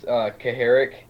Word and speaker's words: Kucharek: Pretty close. It's Kucharek.